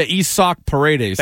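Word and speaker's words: Isaac Paredes: Just like Isak? – Yeah, Isak Paredes. – Thank you. We got to get these pronunciations right.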